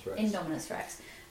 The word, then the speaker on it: Indominus Rex: Things like not suspecting that the Indominus Rex thing is that how you say it? – Indominus Rex.